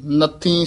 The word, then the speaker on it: नथी: नथी सब जाकी बाखसे मुक्त करायदा ये नथी उच्चारण करना